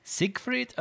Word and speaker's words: Siegfried: Siegfried and Roy. – Siegfried and Roy.